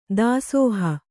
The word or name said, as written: ದಾಸೋಹ